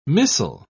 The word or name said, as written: missel